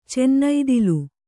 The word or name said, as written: ಚನ್ನೈದಿಲು